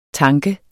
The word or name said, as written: tank